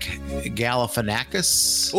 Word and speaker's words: Galifianakis: And last but not least, on Tuesday, October 1st, and I am going to butcher this last name, but Zach Galifianakis.